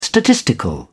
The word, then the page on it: statistical: British English